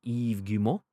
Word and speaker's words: Guillemot: Little was known about Splinter Cell VR beyond a brief tease from CEO Yves Guillemot, which was, quote, "On Splinter Cell, there will be..."